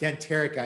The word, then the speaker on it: Denterica: And is pronounced Denterica. I know, Kevin, we talked about that.